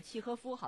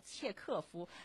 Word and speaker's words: 契诃夫: 俄国的这个世界级短篇小说巨匠契诃夫的一篇书信集结起来的一本书，它很特别。那稍后的话我们可以稍微有一点点介绍啊。一定要说一下啊，可能说起来这个契诃夫，好多人说这契诃夫是谁呀、啊、但如果说另外三个字啊，切克夫，比较熟悉，纠正一下，正确发音是契诃夫。